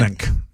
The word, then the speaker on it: Aronimink: Aronimink Golf Club? Is that how you say it? – Aronimink. Yeah, that's how you say it.